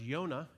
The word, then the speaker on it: Yonah: Well, Jonah's name was actually pronounced Yonah, and if you go up to the North Georgia Mountains, you'll know there is a Mount Yonah up there. It would be actually in Hebrew pronounced that way.